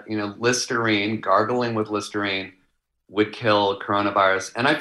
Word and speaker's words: Listerine: Yeah, he said he's been a little skeptical about vaccines, but like Listerine, which rhymes with vaccine, he thinks is pretty good.